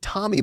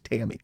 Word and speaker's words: Tammy: And they were pronouncing it Tommy Baldwin, like it's Tammy.